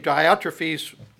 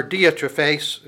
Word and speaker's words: Diotrephes: the name Diotrephes that is being used here, or Diotrephes, if you want to pronounce it more properly, it says that he loves to be first among you.